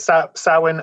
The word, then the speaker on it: Samhain: Yeah, so it's Samhain.